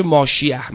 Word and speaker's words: ماشیح: شما میدونید یهودیان هم قائل به منجی هستند، که یک منجی میاد که ماشیح، ماشیح همون مسیحه، که عبریش تلفظش میشه ماشیح، ماسیح، مسیح.